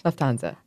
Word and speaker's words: Lufthansa: Lufthansa.